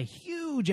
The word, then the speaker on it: HUG: And, yeah, the show is brought to you by Huge Entertainment. – H-U-G. – H-U-G. It's pronounced huge.